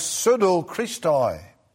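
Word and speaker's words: pseudochristoi: And that single Greek word is pronounced pseudo-Christoi.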